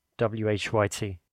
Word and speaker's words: WHYT: You can pronounce each letter, so W, H, Y, T, or you can actually also use the subtitle, which is The Sun is New Every Day.